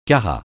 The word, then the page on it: carat: francais